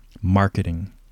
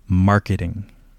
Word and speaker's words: marketing: And so here, where is the stress? In the first, second, or third syllable, where do you hear it? Marketing. Okay, so it's going to be in the first syllable.